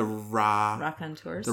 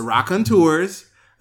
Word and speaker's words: Raconteurs: The Raconteurs. The Raconteurs.